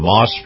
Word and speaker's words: Maus: Moss is spelled M-A-U-S,